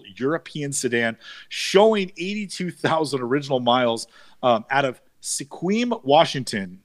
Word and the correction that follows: Sequim: S-E-Q-U-I-M? You are not. – How do you say it? – A lot of people mistake it for Sequim, but it is pronounced Squim. Squim, Washington is out Squim on the Washington.